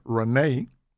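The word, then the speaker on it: Ranee: Contact them today at chicinteriors.us and meet the super sharp, highly creative, goal-oriented, God-led and inspired, exceptional, hands-on service provider, Renee, that's spelled R-A-N-E-E-G-I-L-B-E-R-T, Renee Gilbert, president of Chic Interiors, a excellent full-service interior design firm.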